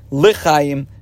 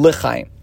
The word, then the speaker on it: Lachaim: Significantly, though, in the Siddur, Alter Rebbe chooses the pronunciation Lachaim for the term in that blessing as well. So, therefore, we pronounce it always Hamidenu Makenu Lachaim.